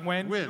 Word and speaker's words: Nguyen: I'm assuming it's Nguyen, right? – Nguyen. It's pronounced Nguyen. – Nguyen, yeah.